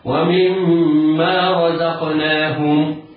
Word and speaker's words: ومما رزقناهم: لا تقول ومما رزقناهم ينفق، هذا خطأ. والصواب ومما رزقناهم،